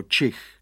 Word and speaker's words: čich: Ale pes Kvik má za ušima a co hlavně má čuch, který by se správně měl vyslovit jako čich.